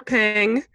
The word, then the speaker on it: Ping: In the first five patrons that we're going to give on-air shout-outs to are Nikki Goodsmith, Roger Ping, and he helpfully gave us a pronunciation, as in penguin, which I thought was funny.